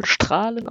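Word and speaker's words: Straelen: Also, was ich dann dort gelernt habe, es schreibt sich ja Strählen, also mit AE, aber es wird wohl Strahlen ausgesprochen.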